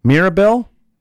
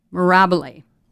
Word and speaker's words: Mirabile: I hope I'm saying it right. Mirabile? – Mirabile. – Yeah, I wasn't even close.